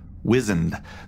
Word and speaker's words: wizened: Bryce Perry writes, loved the episode except for the mispronunciation of the word wizened. The first syllable is pronounced like whiz, not like wise.